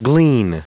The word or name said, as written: glean